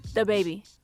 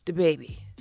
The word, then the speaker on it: DaBaby: DaBaby. – That was pretty good. – I mean, I don't... She put a lot less emphasis on duh.